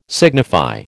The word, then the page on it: signify: American English